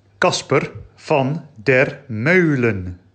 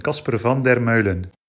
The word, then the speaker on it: Kasper van der Meulen: So, mittlerweile hat der Autor selbst mir gesagt, wie der Name ausgesprochen wird. Und deshalb hört ihr jetzt aus seinem eigenen Munde, wie man seinen Namen richtig ausspricht.